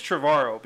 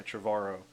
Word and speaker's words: Trevorrow: I think it's Trevorrow because if you take out the Trev, the rest of it is like the end of tomorrow. – Yeah, I think the emphasis is on the V.